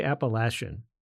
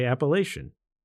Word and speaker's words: Appalachian: If you've spent any time on the AT, you know that there are two distinctly different ways to pronounce the name of the trail. Some folks say Appalachian, while others say Appalachian. Both are correct, and how you say it depends on where you're from.